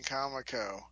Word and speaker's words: Comico: And I'm thinking, that wasn't just a fun, flippant, silly way of saying Comico. You idiots, whoever founded this company, the comic company, felt somehow the English pronunciation was Comico.